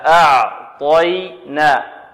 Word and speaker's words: أعطينا: إذن نرقق العين ونفخم الطاء ونرقق الياء، هكذا أعطينا أعطي أعطي، ولا نقول أعطي طاء نرقق الطاء، لا، بل نفخمها طاء. الطاء هنا من حروف التفخيم، وقلنا حروف التفخيم ما هي؟